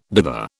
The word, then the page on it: dither: British English